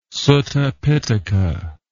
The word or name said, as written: Sutta Pitaka